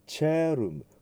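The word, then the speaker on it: caelum: et ascendit in cherum。こっちの方は、 CAE、この AE が 1 個の絵ですのでチェという発音になりますね。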